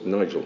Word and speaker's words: Nigel: I said, sir, two syllables, Nigel. He said, okay, Nigel. He couldn't say my name.